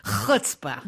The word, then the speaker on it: chutzpah: This is one of those CH words that if you are not born to it, you have a lot of trouble because it's been pronounced, what is it? Chutzpah.